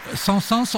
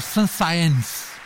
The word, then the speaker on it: Saint-Saëns: o grande Saint-Saëns ou Saint-Saëns, por causa da trema, não é, que obriga a pronunciar o E,